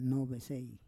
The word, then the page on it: novesei: Roon